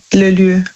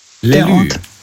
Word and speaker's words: lieu: Also Lü, das schreibt man, also L-I-E und dann U. Lü. – Lelü.